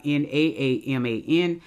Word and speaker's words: Naaman: Now, uh, let me share some information uh, about Naaman or Naaman. His name should be spelled the same in no matter what version of the Bible you have N A A M A N, but my studies show that it is pronounced Naaman.